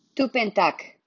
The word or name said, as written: Tuppentak